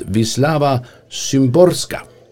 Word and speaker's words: Wisława Szymborska: Och Nobelpristagarinnan i litteratur, hon heter helt enkelt Wisława Szymborska.